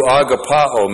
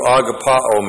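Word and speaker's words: agapaō: In verses 15 and 16, Jesus asks Peter, do you agapao me? A-G-A-P-A long O. Do you agapao me?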